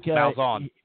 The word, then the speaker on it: Malzahn: Gus Magazin, is that how you say his name? I think Malzahn.